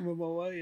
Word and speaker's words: Momoa: Momoa, yeah. – Is that how you say it? – Have you seen it's Momoa.